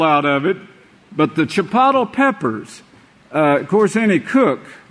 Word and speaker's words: Chipotle: Sister Matthews, she's trying to pronounce that word, and I said, it's Chipotle.